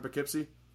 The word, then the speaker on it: Poughkeepsie: You're from Poughkeepsie? – Yeah. – And that's how you pronounce it. I don't want to pronounce it wrong. – No, yeah, you got it spot on.